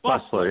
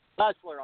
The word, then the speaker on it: Bessler: Is it Doctor Bessler? Am I pronouncing your name? Right? Bessler, yeah. Bessler. – I'm sorry, like you're busting them.